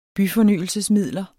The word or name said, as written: byfornyelsesmidler